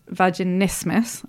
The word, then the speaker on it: vaginismus: So, you know, like things like, and I always mispronounce this, vaginismus,